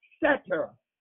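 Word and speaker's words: seter: Now, if we were to take that same word, satar, and break it down to its root word meaning, satar then in the root word is pronounced setter.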